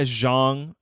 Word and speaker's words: Zhang: Yeah, Zhang.